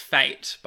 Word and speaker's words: fete: It's pronounced fate, by the way. – Fate? – Like a school fate.